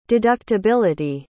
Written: deductibility

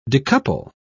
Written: decouple

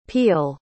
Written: pial

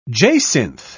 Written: jacinth

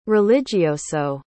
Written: religioso

